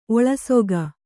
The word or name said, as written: ಒಳಸೊಗ